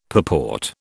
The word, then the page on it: purport: British English